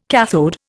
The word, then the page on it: cathode: Indian